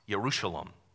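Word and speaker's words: Yerushalayim: The word for Jerusalem is pronounced Yerushalom in Hebrew and means literally the shalom of God.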